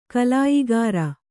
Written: ಕಲಾಯಗಾರ

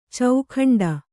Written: ಚೌಖಂಡ

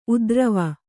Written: ಉದ್ರವ

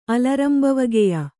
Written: ಅಲರಂಬವಗೆಯ

